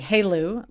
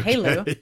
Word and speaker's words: HALEU: Yes, we pronounce it, at least I pronounce it, although I could be wrong, Hey Lou. Like if somebody's name was Lou, right. You say okay. Hey Lou,